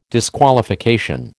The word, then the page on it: disqualification: American English